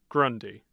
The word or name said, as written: grundy